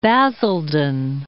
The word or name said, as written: Basildon